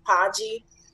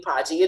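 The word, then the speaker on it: Mpagi: So you're looking for The Garden of Eden, Thieves in the Temple by Courtney Podgy. The last name is M as in Mary. The M is silent. So M as in Mary, P-A-G-I, Courtney Podgy.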